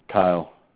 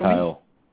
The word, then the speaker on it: Kyle: Kyle. – Sorry? Could you spell it for me? – Kyle.